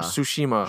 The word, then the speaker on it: Tsushima: I said that wrong, Tsushima. – Tsushima.